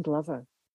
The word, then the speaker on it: Glover: Merryn Glover.